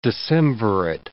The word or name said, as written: decemvirate